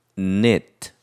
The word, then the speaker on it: knit: 그래서 코바늘을 넣다 해서 뜨개질하다 이런 뜻인데 옛날에는 K 발음을 했어요. 그러나 K는 묵음이 되어서 knit 이렇게 하면 되는 거죠. 그럼 이렇게 코바늘에 실을 넣는 거, 그래서 매듭을 만드는 거를 K-N-O-T, 거기 어원프레드 나왔죠.